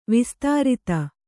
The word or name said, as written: ವಿಸ್ತರಿತ